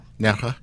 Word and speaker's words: Nerja: Nerja. Is that how you say it? Nerja? N-E-R-J-A? Nerja? – Nerja.